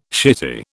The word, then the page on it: shitty: British English